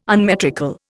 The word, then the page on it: unmetrical: Indian